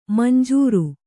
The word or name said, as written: ಮಂಜರಿ